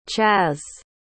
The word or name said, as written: chazz